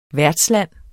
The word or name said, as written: værtsland